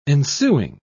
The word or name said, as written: ensuing